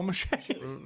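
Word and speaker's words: mâché: It's M-A-C-H-E. – Just like you'd think it'd be. – Just like you'd think it Is it French? – It's mache.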